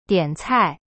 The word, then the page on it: 点菜: dian cai